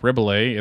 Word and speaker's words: Rebillet: And so, anyways, if you haven't heard of him, Mark Rebillet, it's like R-E-B-I-L-L-E-T. So it's like, you know, like the little silent T situation going on.